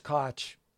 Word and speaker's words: Koch: So Ellers Koch, rhymes with watch, his last name, 40 years of Forester.